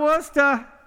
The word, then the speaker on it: Worcester: Yeah, Worcester. – That's how you say that.